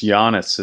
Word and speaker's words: Giannis: It's just Giannis. It's like a Y instead of a Giannis Antetokounmpo.